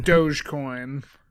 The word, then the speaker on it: Dogecoin: I do believe it's Dogecoin. – Dogecoin.